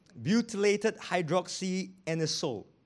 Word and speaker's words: butylated hydroxyanisole: You want to know how to pronounce it? I'll try slowly. Butylated hydroxytoluene. Butylated hydroxyanisole. BHT, BHA.